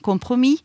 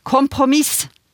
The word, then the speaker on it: kompromiss: I eh, ordbok over det danske språk så oppgis faktisk både uttalen med s og den uten s. Og der står det også at ordet er lånt inn i dansk fra fransk kompromiss eller fra tysk kompromiss.